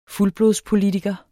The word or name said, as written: fuldblodspolitiker